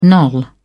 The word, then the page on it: knoll: British English